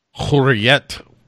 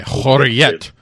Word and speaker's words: Hürriyet: How is it pronounced? – Hürriyet. – Okay, Hürriyet. Hürriyet.